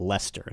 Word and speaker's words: Leicester: They leave out at least three letters, just Leicester.